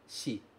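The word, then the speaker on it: shi (四): Tá aí, aparecendo com a pronúncia shi.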